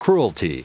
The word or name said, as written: cruelty